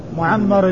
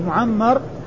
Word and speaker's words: معمر: معمر الميم مشددة والعين مفتوحة ومعمر العين ساكنة والميم مفتوحة رسمهما واحد والفرق بينهما إنما هو في النطق وإلا فإن الرسم واحد. معمر